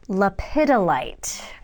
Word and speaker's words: lepidolite: The seventh one is, I have to say this right, lepidolite. I apologize to everyone. I used to call it lepidolite. That is not how it's pronounced. It's lepidolite.